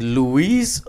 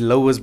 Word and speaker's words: Lois: And the struggle when I met her the first time, the struggle was, is it Louise or is it? I heard, no, it's Lois.